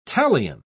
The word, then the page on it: talion: British English